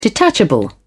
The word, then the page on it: detachable: British English